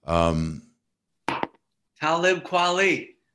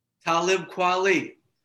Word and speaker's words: Talib Kweli: It's Talib Kweli. – Talib Kweli. – Yeah, I better say his name right. – Talib Kweli.